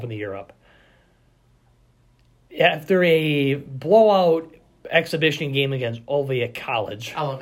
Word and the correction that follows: Olivet: Not quite. Not even close, trust me. Olivet recruited me to play college football, trust me. It's pronounced Olivet.